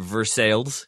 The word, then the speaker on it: Versailles: There is, this is their pronunciation, Versailles, Kentucky.